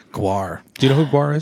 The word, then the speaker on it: GWAR: I did proceed to tell them that I went to a Gwar concert too.